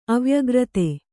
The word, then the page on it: ಅವ್ಯಾಕೃತ: avyākřta